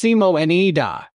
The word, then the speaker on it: Simonida: Her name, Simonida, pronounced Simo-N-E-Da, is rare in present-day Serbia.